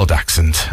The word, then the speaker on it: dachshund: Yes, he's a little dachshund. – That's how you said it properly.